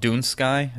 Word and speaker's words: Dunscaith: Dunsky is the correct pronunciation of Dunscaith.